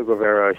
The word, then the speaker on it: Guevara: And Mario Guevara, am I pronouncing that correctly, on pencil? – Yeah, so? Mario Guevara.